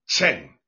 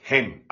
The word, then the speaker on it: חן: Now, the English we will say chen. But in the Hebraic pronunciation, the C becomes silent. So it becomes like hen, as in English hen.